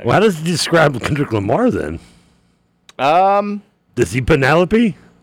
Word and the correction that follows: panoply: I don't think he's got a panoply. – Penelope, Lamar, I can't pronounce the word right.